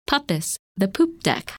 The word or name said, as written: Puppis